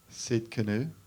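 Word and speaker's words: Tsidkenu: Sid Canoe, right? Yeah, that's how you pronounce it. Jehovah Sid Canoe.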